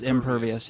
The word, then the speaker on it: impervious: Yeah, in some communities require that you know, only a certain percentage of the lot of your property is allowed to have impervial. Am I saying that right? – Yeah, pervious versus impervious.